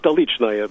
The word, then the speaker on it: Stolichnaya: I say Stolichnaya because I speak Russian, and that's the way the people that make it say it.